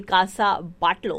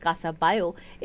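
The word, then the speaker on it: Casa Batlló: And Casa Bayo ko actually agar likha jayega to aisi kuch pronunciation hogi ki Casa Batlo, lekin it's pronounced Casa Bayo, is one of Anthony Gaudi's most imaginative masterpieces and is often called the most magical house in Spain.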